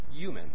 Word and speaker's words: UUMAN: this one is UUMAN, which they pronounce human, and rhymes with human.